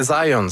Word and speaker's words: Zajonc: Non, c'est Zajonc. – Ouais, OK. – J'ai-tu pas dit Zajonc? – Vous avez dit plus Zajonc, mais. – Parce que j'ai prononcé un petit peu à l'anglais. J'ai prononcé mon polonais à l'anglais.